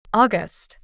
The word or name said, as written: August